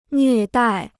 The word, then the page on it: 虐待: nlüè dài